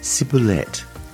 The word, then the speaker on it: ciboulette: Chives in French is pronounced ciboulette and spelt C-I-B-O-U-L-E-T-T-E.